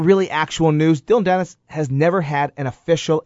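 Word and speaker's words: Dillon Danis: His training partner, how do you say this, Dylan Dennis? – Yes, that's how you say it.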